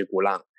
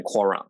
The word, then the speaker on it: Quran: 可是今天我们介绍这本经典是《古兰经》，或者叫做《可兰经》，好看你怎么翻译哈，因为它的它的阿拉伯语的念法是古朗，然后或者英文是念成 Quran 啊，所以有些人会念成《可兰经》或者是《古兰经》，那我觉得都可以。